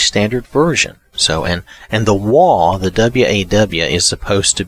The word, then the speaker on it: Waw: The next one that we haven't read is Waw, W-A-W. And the way I pronounce it, I know, probably sounds horrible with my accent, but it's Waw, Waw, W-A-W.